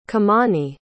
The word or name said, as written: kimani